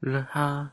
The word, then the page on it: lweha: Lwaitel